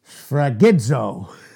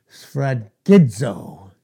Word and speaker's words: sphragizo: Sphragizo. It's quite a word. Sphragizo in the Greek. It's spelled S-P-H-R-A-G-I-Z-O.